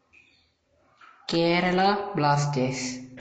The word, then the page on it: Kerala Blasters Football Club: / k ɛ r ə l ə b l ɑː s t ə z /